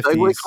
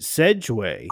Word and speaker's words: Segway: Segway company? – No. She pronounces it Sedgway. And it fucking threw me off when she said it, but I didn't want to correct her because I don't want to be that guy.